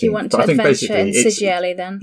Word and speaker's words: Sigil: There's a 96-page guide to Sigil. Not Sigil. Sigil. It is correctly pronounced.